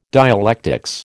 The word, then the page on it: dialectics: American English